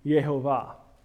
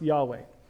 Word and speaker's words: Yahweh: And that's how you get the pronunciation Yehovah. Jehovah, Yehovah, Yehovah, they're all the same. It points to this word, which I believe is better pronounced Yahweh.